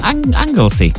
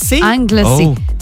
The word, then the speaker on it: Anglesey: Did I say that right? – Yeah. Anglesey. – Anglesey.